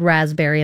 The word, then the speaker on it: raspberry: I got second place in the spelling bee because of the word raspberry and the silent P.